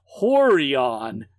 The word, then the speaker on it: Rorion: So, one of the founders of UFC was a Brazilian jiu-jitsu teacher named Rorion. Pronounced Horion.